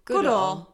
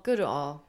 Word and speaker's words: Goodall: Goodall. Goodall.